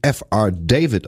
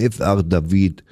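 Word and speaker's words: F. R. David: Ja, der wird tatsächlich nicht F.R. David ausgesprochen, der Mann, der jetzt kommt, sondern der hat erstmal gesagt selber, hello, I'm F.R. David.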